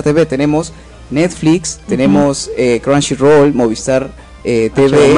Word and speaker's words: Crunchyroll: Vayan a verlo a Chunchirol. Crunchyroll. Exacto, Crunchyroll.